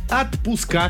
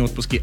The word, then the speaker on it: отпуска: Далее, козыри, отпуска, все-таки не отпуски, отпуска.